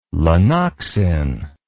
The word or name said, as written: lanoxin